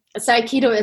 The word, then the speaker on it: Aikido: So Aikido is a Japanese word. It's a Japanese martial art, and it consists out of 3 syllables, I, Ki, and Do.